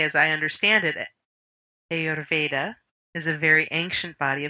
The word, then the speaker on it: Ayurveda: A simple way of doing it is to just say Ayurveda. – Ayurveda. – Yeah. – And Ayurveda. – If you can roll your R's, I can't do it very well, but if you can, that helps. – Ayurveda.